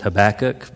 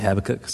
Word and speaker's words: Habakkuk: I've always said it Habakkuk, but I've heard it pronounced recently Habakkuk. So I think you can go either way with that.